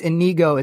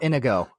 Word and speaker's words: Inigo: All right, if you're going to call me out on mispronouncing your name, Dick Monkey, it's Inigo, it's not Inigo. – Yeah? – It's not the Indigo Girls.